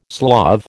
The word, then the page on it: Slav: American English